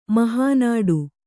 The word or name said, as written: ಮಹಾನಾಡು